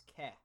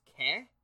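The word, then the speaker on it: qu'elle: Quel is pronounced as ke. Q-U apostrophe E-L-L-E is pronounced ke.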